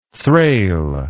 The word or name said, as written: thrale